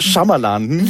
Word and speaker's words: Shyamalan: Night Shyamalan, glaube ich. – Ja, Shyamalan.